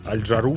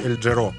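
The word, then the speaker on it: Al Jarreau: Я Аль Джару всегда думала. Мне выдавали каких-то э, этих террористов арабских. А оказывается, читается Эль Джаро.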